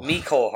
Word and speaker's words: Mecole: Mecole Hardman.